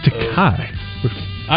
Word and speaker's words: Takei: George Takei. Do we have any of that? – I always thought it was Takei. – I've heard it pronounced both ways. I'll accept it either way as the adjudicator of said game.